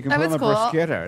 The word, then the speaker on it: bruschetta: Yeah, bruschetta. – Bruschetta, you're supposed to say. – If we're pronouncing it correctly.